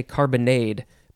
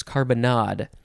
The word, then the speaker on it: carbonnade: I really want to say carbonade, but it is carbonade. C-A-R-B-O-N-N-A-D-E, so there's two N's, or you could spell it with just one N.